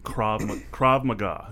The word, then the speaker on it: Krav Maga: So I've always heard of Krav Maga. That's mm-hmm. how you pronounce it, correct? – Yep.